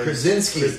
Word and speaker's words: Krasinski: There was the thing John Krasinski. I think that's how you say his name. – Krasinski. Everybody likes John Krasinski.